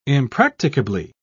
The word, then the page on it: impracticably: British English